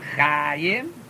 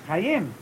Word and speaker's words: Chayim: In general, all accentation of any word is on the last syllable unless it's on next to the last syllable, for whatever reason may be. Chayim. It's not Chayim. It's Chayim. It's not Adoloi, It's Adoloi.